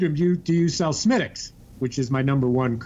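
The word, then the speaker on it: Smithwick's: As I said, it's pronounced Smittix, but it's spelled Smithwix.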